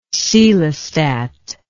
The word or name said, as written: coelostat